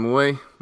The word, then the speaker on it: Mui: Hey everyone, I'm here with Jonathan Mui, my friend. Did I say it right, Jonathan? – Yes, that's correct. – Like Mui Bien? – Exactly.